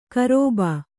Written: ಗರ್ಭ

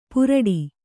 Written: ಪುರಡಿ

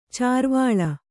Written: ಚಾರ್ವಾಳ

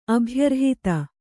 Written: ಅಭ್ಯರ್ಹಿತ

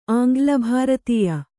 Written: ಆಂಗ್ಲಭಾರತೀಯ

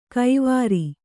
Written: ಕೈವಾರಿ

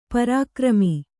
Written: ಪರಿಕ್ರಮ

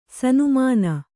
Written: ಸನ್ಮಾನಿ

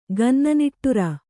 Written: ಗನ್ನನಿಟ್ಟುರ

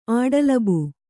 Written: ಆಡಲಬು

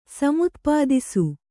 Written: ಸಮುತ್ಪಾದಿಸು

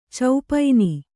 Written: ಚೌಪೈನಿ

